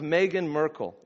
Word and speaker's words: Meghan Markle: Think of Meghan Markle.